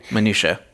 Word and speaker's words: minutiae: In American slang, the term inside baseball refers to the minutiae, I think I'm saying that right. – Minutiae.